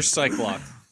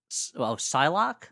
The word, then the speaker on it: Psylocke: You're Cyclock. – Cyclock. So the guy was a clock instead of an eye. – How do you pronounce that? – Psylocke. – Yeah, I'm Psylocke.